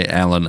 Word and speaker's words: Alyn: I'm assuming most people know, but my name is spelled A-L-Y-N. So when we say Alan and AJ, there's an L and a Y in there, which might not be immediately obvious.